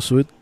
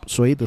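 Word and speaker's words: Swede: Así que de fondo, ahí tienen Hooked on a Feeling de Blue Suite, o Suede creo que es Suede, es como se pronuncia.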